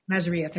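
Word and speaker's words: Mazria: And then I heard Ed Mazira, that's how you say his name. – Mazaria, thank you.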